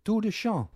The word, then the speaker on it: Tour de Chant: Maar er is ook zoiets als Tour de Chant. Spreek ik het goed uit? – Ja, dat spreek je goed uit.